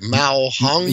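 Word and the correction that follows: ma huang: Yeah. Mao Huang.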